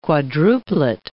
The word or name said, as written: quadruplet